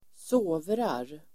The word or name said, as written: sovrar